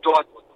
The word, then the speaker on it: Dortmund: Ah, lo puedo hablar también, pero hablo el deje de la cuenca del Ruhr y ahí se pronuncia Dortmund.